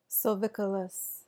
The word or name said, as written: silvicolous